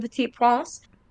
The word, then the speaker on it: Petit Prince: They adapted the story of the Little Prince, which is a French children's story, the Petit Prince, and That was a good pronunciation right there.